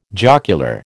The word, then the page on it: jocular: American English